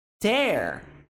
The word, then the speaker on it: tear: Então, essa palavra, quando é pronunciada tear, tear, tear, escrito assim, T-E-A-R, mas pronunciada tear, ela significa rasgo. Quando essa mesma palavrinha é pronunciada tear, opa, tear, ela significa lágrima.